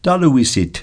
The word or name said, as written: taluisit